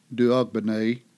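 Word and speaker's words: D'Aubigné: Merle DeBing, the American pronunciation is often Duogbene,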